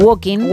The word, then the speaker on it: walking: en la L, al igual que ocurre en Walking.